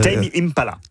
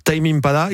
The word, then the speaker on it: Tame Impala: Tame Impala. – Tame Impala.